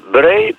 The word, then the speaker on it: Broglie: Oddaliła żądanie Nicola de Breuil, wymawia się Breuil, pisze się Broglie, urodzonego w 1987 roku o przywrócenie mu tytułu Duka.